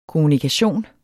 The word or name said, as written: kommunikation